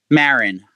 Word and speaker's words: Maren: Maren.